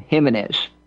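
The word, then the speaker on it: Gimenez: Carlos, I'm assuming he pronounces his name Jimenez, but it's spelled with G. G-I-M-E-N-E-Z. By the way, it's worth going back to Mario Diaz-Balart. That's spelled D-I-A-Z hyphen B-A-L-A-R-T.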